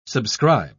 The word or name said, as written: subscribe